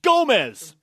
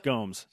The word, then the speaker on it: Gomes: It's Gomez. – Portugal dog. – Nope. – She ain't from Nicaragua. – It's Gomes. – It's from Portugal. It's Gomes. – And it's, yeah. – The native pronunciation. – Is Gomes.